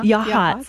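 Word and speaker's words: Yachats: And as you shared earlier, you've got big, but 7 counties, you said, all the way from, how do you say that? Yahats? – Uh-huh. – Yahats. – Yahats. On the coast.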